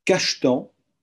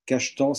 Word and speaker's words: Cajetan: Il y a Cachetan. Cachetan, ça s'écrit C-A-J-E-T-A-N.